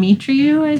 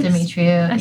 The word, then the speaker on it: Demetriou: I don't know how to pronounce his last name. – Demetriou. – Demetriou.